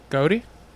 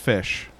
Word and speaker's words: ghoti: G-H-O-T-I. – Goaty? – No, it's actually pronounced fish. – Oh. – It's intended to be pronounced the same way as fish using these sounds. The G-H pronounces F as in enough. Okay. The O is pronounced as like an I as in women. And then the T-I pronounced as S-H, as in like nation or motion.